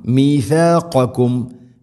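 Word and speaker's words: ميثاقكم: മീസാഖക്കും മീസാഖക്കും എന്നല്ല മീസീനാവരുത്. അതുപോലെ ഖാഫും കാഫും മഖ്റജ് അടുത്തുള്ള അക്ഷരമാണ്. മീസാഖക്കും മീസാഖക്കും.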